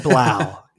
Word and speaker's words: Blough: His last name is not pronounced like it's spelled. Oh God, you're his first name is the same as your first name. – Oh, Blough? – David Blough.